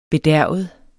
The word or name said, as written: bedærvet